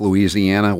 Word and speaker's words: Louisiana: Is it that hard to pronounce Louisiana?